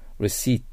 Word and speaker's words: receipt: receipt、 P を発音しません。